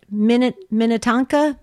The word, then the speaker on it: Minnetonka: Julie is first here on take two, and Julie is in, let me see if I can get this right, Minnetonka, Minnesota, on Sirius XM 130.